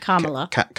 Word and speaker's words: Kamala: And this is all because Kamala Harris, that's how you say it, correct? – Kamala.